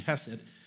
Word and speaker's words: Hesed: So I'm just going to use the more anglicized pronunciation, Hesed.